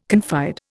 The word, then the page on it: confide: Indian